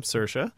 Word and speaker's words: Saoirse: See, I can say her name, Saoirse.